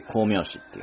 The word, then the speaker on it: 光明子: 光明子、光に明るいに子供の子と書いて光明子っていうね、なんかのヒロインみたいな名前ですけども、 1776 年アメリカの潜水艇タートルが、イギリスの戦列艦イーグルを攻撃、世界初の潜水艦攻撃ですね。